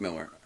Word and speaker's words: Millar: And, you know, that's why everyone says Millar, even though it is pronounced Miller. – Yeah. Mm-hmm. Like, I think Mark actually went on Twitter because someone asked him how to pronounce his name, and he said either one is fine.